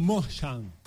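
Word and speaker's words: München: El señor Olise, el jugador del Bayern, del Bayern Mönchen, porque no se dice Bayern Múnich, usted sabía eso, ¿no? – ¿Ah? – Bayern Mönchen. – Bayern Múnich. – No se dice Bayern Múnich, está mal dicho.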